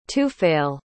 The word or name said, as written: tufayl